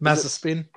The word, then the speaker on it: Mazepin: Marzipan. – Most people do. – Is it Mazepin? – I can't say his name properly. It's Marzipan.